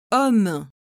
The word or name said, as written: homme